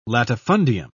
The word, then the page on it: latifundium: British English